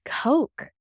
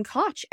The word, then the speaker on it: Koch: I think I just found out, Rachel told me like a couple of years ago, that it's actually pronounced Coke. – Wow. – But we always called him Koch.